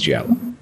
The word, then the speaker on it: giallo: I assume it's a soft G, like gelato. – Yeah. They said that, like, you kind of skim across the G and leave a little bit of Y there, because it's yellow, right? – Giallo.